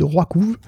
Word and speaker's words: RWKV: Bon, on va justement parler de Dolly et de GPT-4all juste après, mais si on prend l'exemple de Roikov, je sais, ça s'écrit de manière encore plus imbitable que ça se prononce, hein, je vous rassure. ça s'écrit RWKV.